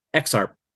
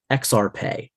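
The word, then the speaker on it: EXARP: Some people say it's said exactly like it's written, and some people will say that it sounds more Latin when you read it. So I'll give you an example. The word that's spelled E-X-A-R-P. I believe it's the spirit of air, the element of air. So some people will say XARP, and some people will say XARPE.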